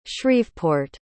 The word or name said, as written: shreveport